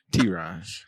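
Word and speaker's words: Tearon: It's T-E-A-R-O-N, period. T-Ron. – If anybody hears you now, they'll think you're on your period. T-E-A, period.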